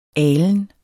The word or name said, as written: alen